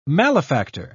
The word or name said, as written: malefactor